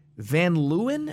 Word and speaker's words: Van Leeuwen: I think because they work with partnership with, there's this ice cream brand, Van Leeuwen. Am I pronouncing that correctly? – Yeah, they're the ones that always do those stupid concoctions.